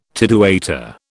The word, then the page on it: tidewaiter: British English